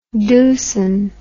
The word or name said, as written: dusun